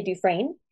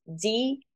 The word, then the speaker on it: Dufresne: My Instagram tag is the Ashley Dufresne. And my last name is spelled Dufresne. It's the Ashley Dufresne.